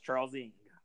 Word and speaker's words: Charles Ng: Leonard Lake and Charles N. – Dude, I almost put them down there, but go ahead. Oh, that's a good pick. – First of all, it's pronounced Charles Ng.